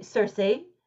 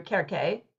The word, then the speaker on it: Circe: So I say Circe. Before Madeline Miller's book came out, I always called her Kerke, which is like the Greek way to say her name in English.